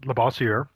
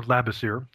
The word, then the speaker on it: Labossiere: Um, my name is Mike uh, Labossiere or Labossiere